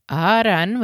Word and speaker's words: aran: "Aran," with emphasis on the first long A, is bread.